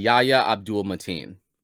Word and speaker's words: Yahya Abdul-Mateen: Yahya Abdul-Mateen.